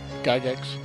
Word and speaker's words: Gygax: Gygax.